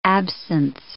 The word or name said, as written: absence